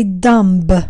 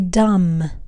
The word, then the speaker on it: dumb: Number eight, it's not my favorite word, but I really want to talk to you guys about this word because many students make the mistake when they pronounce this word, which is D U M B. Many students say dumb, wrong. The B is actually silent in this case. So you say dumb, dumb Number nine, character.